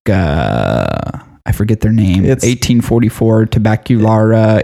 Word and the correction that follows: Tabaquería: 1884. – Tabaquera. – Yeah, I never know how to pronounce Spanish words. – Tabaquería.